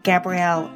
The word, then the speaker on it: Gabrielle: You can follow me on Twitter to get updates about new podcasts and more, and that's at Gabrielle Author. My first name is spelled G-A-B-R-I-E-L-L-E.